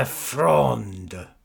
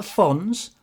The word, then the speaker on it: Fronde: Not the Fons, the Fronde. Do you want me to spell it for you? – Yes, please. – F-R-O-N-D-E, silent E, unless it's Fronde. – But I don't know, if it's not got an acute accent on, then it's silent, it's Fronde.